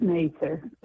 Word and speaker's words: Mather: It's Mather. That's the way they pronounce Mather.